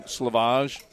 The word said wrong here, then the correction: Sleevage: right? – And he's like, it rhymes with cleavage. It's sleevage.